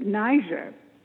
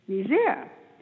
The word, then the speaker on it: Niger: You know, it's some people pronounce it Niger. And yet it was a French colony, so the correct pronunciation is Niger.